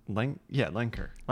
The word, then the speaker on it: Lenker: I think Lenker.